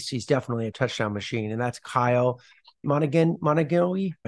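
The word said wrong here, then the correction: Monangai: I can't pronounce his name. It's Monangi.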